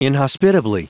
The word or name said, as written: inhospitably